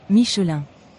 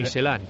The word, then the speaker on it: Michelin: La N no suena. Michelin. – Michelin. – Michelin. – Pero la N sí suena en francés, ¿no? Es en inglés donde se cortan ahí muchas letras. Si Michelin.